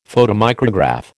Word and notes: photomicrograph: American English

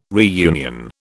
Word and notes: reunion: British English

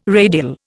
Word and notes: radial: Indian

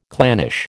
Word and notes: clannish: American English

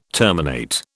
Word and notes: terminate: British English

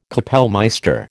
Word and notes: kapellmeister: American English

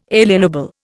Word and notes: alienable: Indian

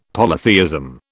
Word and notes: polytheism: British English

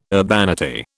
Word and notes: urbanity: British English

